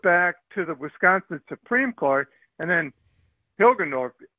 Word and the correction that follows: Hagedorn: or whatever his name is, Hilgeborn, or Hagedorn. – I can't get the name wrong. – Hagedorn.